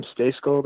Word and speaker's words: Stejskal: And then I'm on Twitter at Sam Stejskal. That's S-T-E-J-S-K-A-L for those of you that don't know how to spell that.